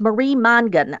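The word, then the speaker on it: Mongan: I would also say get Marie Mangan's book on hypnosis or hypnobirthing. Okay. Yeah, it's Marie Mangan. I'm sorry, I mispronounced her name. It's M-O-N-G-A-N.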